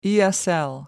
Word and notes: esl: American English

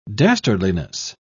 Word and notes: dastardliness: British English

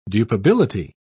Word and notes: dupability: British English

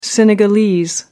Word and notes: senegalese: American English

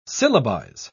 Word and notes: syllabize: British English

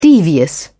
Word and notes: devious: British English

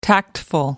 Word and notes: tactful: American English

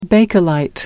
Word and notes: bakelite: American English

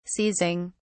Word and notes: seizing: British English

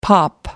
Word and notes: pop off: American English

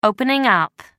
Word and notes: opening up: American English

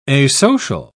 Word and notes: asocial: British English

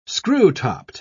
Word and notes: screw-topped: British English

